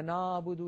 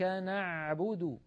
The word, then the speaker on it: نعبد: العين الفصيحة أعمق بقليل من العين التي اعتدنا أن ننطقها بالعاميات. يعني مثلا كثيرا ما نسمع إياك نعبد نعبد، يعني الصوت إيش هذا نعبد، لابد من أن نعمقه، إياك نعبد هكذا العين الفصيحة.